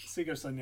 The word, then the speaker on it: Sigurdsson: It's Brian like Sigurdsson, I think. Sigurdsson, yes, is uh, cor- is the correct way to pronounce it.